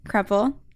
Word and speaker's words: Kreppel: Hi, I'm Kelsey Kreppel.